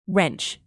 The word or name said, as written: wrench